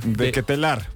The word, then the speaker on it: De Ketelaere: De Ketelaere.